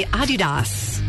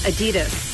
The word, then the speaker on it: Adidas: It's actually supposed to be Adidas. – Really? – Yeah, slight emphasis on the first syllable. Not Adidas. – Adidas. – Adidas. – All right. – Yeah. That's German. It's a German company, so.